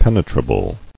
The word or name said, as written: Penetrability